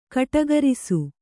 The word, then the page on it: ಗಟುಕರಿಸು: Kannada